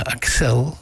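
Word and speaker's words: Axelle Red: Ja, Axel, ja, zo spreek je het echt uit, Axel Red.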